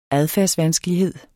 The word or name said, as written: adfærdsvanskelighed